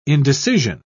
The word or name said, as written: indecision